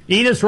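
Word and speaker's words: Enes: Enos rhymes with penis.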